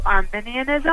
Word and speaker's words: Arminianism: Okay, so I was recently checking out your table that compares Calvinism to Arminianism.